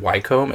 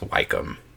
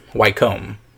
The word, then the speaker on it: Wycombe: I think I said Wycombe. It's Wycombe. – Okay. BTWs. I don't know that I hear the difference. Say it Wycombe. – Again? – Wycombe. – Wycombe is what it is? – Wycombe.